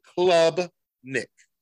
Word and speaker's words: Klubnik: Club Nick.